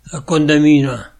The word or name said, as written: Condamine